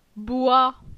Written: Boi

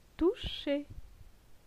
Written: Toucher

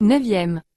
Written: 9e

